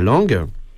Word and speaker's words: Lange: Donc, c'est un livre de Sacha Lang. Ça s'écrit Lange en français, hein, mais bon, on m'a dit qu'il fallait prononcer Lang.